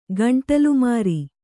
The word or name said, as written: ಗಂಟಲುಮಾರಿ